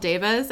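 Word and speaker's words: daevas: I know they're called devas, and it's spelled D-A-E-V-A, but I'm just going to call them divas.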